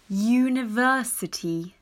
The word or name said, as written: University